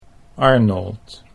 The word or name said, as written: Arnold